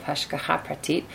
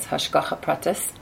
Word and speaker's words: Hashgacha Pratit: Here I want to introduce, everybody's aware, I'm sure, in this class of the concepts of Hashgacha Pratit, or sometimes pronounced as Hashgacha Pratis.